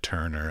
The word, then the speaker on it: Turner: In Czech, we pronounce it in Czech. So it's not Turner, it's Turner. – I know. I would have said Turner, actually.